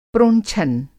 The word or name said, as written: प्रोंछन